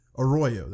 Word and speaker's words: Arroyo: The JATO project actually got the boot from Caltech for their loud, damaging experience, so they moved back to the Aurora Seiko. – All right. – Arroyo, that's how you say it, sorry.